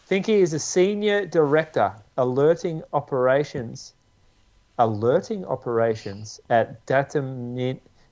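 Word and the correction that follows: Dataminr: I think it's Data Miner. I think it's how you pronounce it. – Oops. Oh, Data Miner. Yeah. Okay. Yeah, that makes sense. Data Miner, but with no E.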